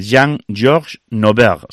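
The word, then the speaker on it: Jean-Georges Noverre: Se celebra, a ver si lo pronuncio bien, es el centenario, o mejor dicho, es la fecha del nacimiento de Jean-Georges Nauberg. ¿Se dice así? – Sí.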